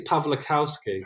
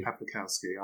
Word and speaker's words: Pawlikowski: Pawlikowski. Is that the correct pronunciation? – I think it's Pawlikowski. – Pawlikowski.